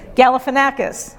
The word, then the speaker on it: Galifianakis: A woman named Mimi Haist found her unlikely God sent messenger in the comedian Zach Geffen, I can never say his name, Galifianakis.